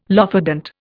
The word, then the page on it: lophodont: Indian